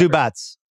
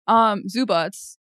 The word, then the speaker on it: Zubac: How do I pronounce his name? I've been saying it forever. – Zubats. I love calling him Zubac. As you know, I have pronunciation dyslexia, but it is Zubats.